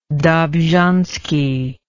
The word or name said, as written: dobzhansky